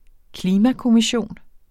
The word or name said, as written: klimakommission